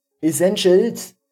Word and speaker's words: Essentials: Also, er hat jemanden, Freunde, Freundinnen, ein Pärchen, die haben seine Essentials, wie wird das jetzt ausgesprochen? Das Wort ist schon so verhurt, dass ich es nicht mal mehr ordentlich... – Essentials. – Ice Angels. Wir sagen jetzt Ice Angels dazu.